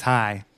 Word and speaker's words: Chai: It's spelled C-H-A-I, but it's pronounced Hi.